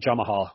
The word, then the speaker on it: Jamahal: Start with light heavyweights, Paul Craig, and I'll call him Jamal Hill, but it's really spelled Jamahal, which is how I think it should be pronounced.